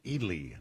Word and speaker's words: Ely: They tell me it's pronounced Ely, Nevada. Ely, Nevada.